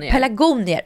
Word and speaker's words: pelargonier: Jag kan liksom inte uttala det här. Pelargoner. – Pelargonier. – Och jag säger alltid pelargoner.